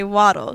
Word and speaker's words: Waddle: I always say Waddle like a duck. Just Waddle like a duck, W-A-D-D-L-E.